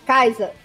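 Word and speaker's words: Kaixa: Mas se fala. Se lê Kaisa. Kaisa. Mas se escreve Caixa.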